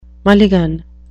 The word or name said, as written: mulligan